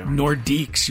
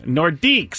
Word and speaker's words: Nordiques: Nordiques. You can pronounce it. Try it. – Nordiques.